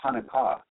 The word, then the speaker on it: Hanukkah: And so I looked it up, of course, in Hebrew, it's more of a CH, like Hana, and it's extremely similar to the first portion of the word Hanukkah, because that's Hanukkah.